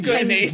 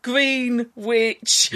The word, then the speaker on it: Greenwich: Greenwich. – Yeah, like sandwich. But green. – Yeah. – Classic small piece. – I liked the play on this one. I mean, this one was about Greenwich time.